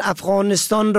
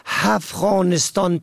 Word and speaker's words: افغانستان: مثلا افغانستان را که با الف شروع می شود با ه دو چشمه هفغانستان تلفظ می کنند.